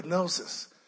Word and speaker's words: gnosis: He said, gnosis. I can't pronounce it the way he pronounced it. He told me there's a g in Greek. There's a g in Greek. Gnosis.